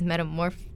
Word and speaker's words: Metamorphoses: And the fourth is Metamorphoses.